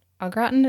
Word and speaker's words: au gratin: Au gratin, is that how you say it? – Yeah.